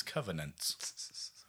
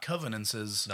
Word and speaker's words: covenants: and plural is covenants. Not covenances.